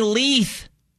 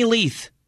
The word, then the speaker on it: Leuth: We've been saying it wrong. Quarterback for the UNC Bears, Kenny Luth, L-E-U-T-H. Coach Lamb has said as much. It's Kenny Leith. It's Kenny Leith.